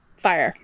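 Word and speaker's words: Fyre: I mean, he was trying to con more people out of their money while he was being investigated for the Fry Festival. – Yes. – So. – Fire. – Fire Festival, sorry. – I would go to a Fry Festival if I could. – I think it's because it's spelled so weird. Like, yeah. – I see it and I think fry.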